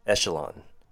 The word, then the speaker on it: echelon: And our last one would be echelon. Three syllables. E has a short E sound, SH sounds like SHUH with a short U sound, LON sounds like the word on but with an L in front. Echelon.